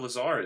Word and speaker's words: Lazar: Is that how you pronounce his name? I already thought it was Lazarus. Is it Lazarus? – Lazar. – Oh, shit. – Just Lazar.